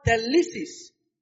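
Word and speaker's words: thelesis: The writer, okay, the word will also is the Greek word telesis. That's the Greek word. Let me spell it T-H-E-L-E-S-I-S. T-H-E-L-E-S-I-S.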